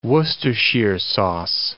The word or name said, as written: Worcestershire sauce